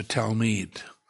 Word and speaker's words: Talmid: It is the Hebrew word Talmid, T-A-L-M-I-D. Actually, it should be pronounced Talmid, Talmid.